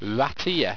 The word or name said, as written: wrateer